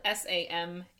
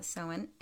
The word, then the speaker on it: Samhain: Anyway, so the Irish meaning of Samhain is summer's end. – Huh. – Ta-da. It's Gaelic, and that's why it's spelled so much differently than it is pronounced.